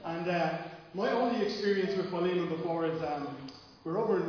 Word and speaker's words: Philemon: So I don't want to speak from the book of, I always called it Philemon, right? But as I've listened to stuff this week, it's pronounced Philemon, but I'll probably say Philemon, Philemon, loads of different ways during the sermon as I forget how it's pronounced.